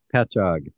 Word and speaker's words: Patchogue: It's Patchogue, New York. – Patchogue. – Patchogue. – Did you actually look that up? – Yeah, I did, because I didn't know. – I was going to say Patchogue.